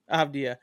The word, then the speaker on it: Avdija: I can never know how to pronounce his last name. Denny Avdija. – Denny Avdija. – You pronounce it? Avdija.